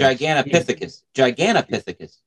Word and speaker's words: Gigantopithecus: I am a huge proponent of the Gigantithecus theory that Gigantithecus, however you want to pronounce it, was been around since... – Gigantopithecus.